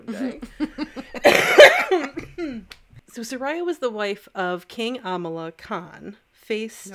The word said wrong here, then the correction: Amanullah: Nope. Amanullah. Do you want me to do it phonetically? – God. Yeah. – Okay. Amanullah. Does that help? Amanullah.